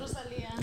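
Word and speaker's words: Rosalía: Rosalia.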